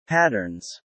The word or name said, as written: patterns